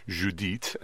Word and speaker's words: Judith: Catherine Webster will be Judith, or Judith, as it would be pronounced by the French, and Laura Himes is Esther.